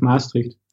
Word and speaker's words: Maastricht: So you're studying in Maastricht. I butchered that pronunciation. And what's the name of the school? – The Conservatory of Maastricht.